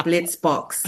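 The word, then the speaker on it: Blitzboks: So it's the Blitz Box. Yeah. – There we go. – That's hard it is? Yeah. The name of champions is always so hard to pronounce, but, you know, it's not nothing bias or anything going against South Africa.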